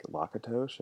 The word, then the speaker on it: Lakatos: Wait, also, it's pronounced Lakatos? – I think so. I always used to say Lakatos, and then I was talking to a philosopher friend, Mark Alfano, and I was like, how do you say it? And he's like, Lakatos? And I was like, okay, I'm going to change that now.